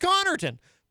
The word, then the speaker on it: Connarton: So in my case, you know, my last name is Connarton. But in high school, my nickname amongst my friends became Connarton. And the reason for that is, and obviously it's not pronounced Connarton, it's pronounced Connarton. But what would happen is inevitably,